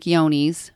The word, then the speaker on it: Johne's: And unfortunately, one of those diseases is called Johne's and it's spelled with a J. It looks like John, J O H N E S. It's pronounced like Yoni's. Um, if you have goats on your property that have that disease, it is carried in the feces.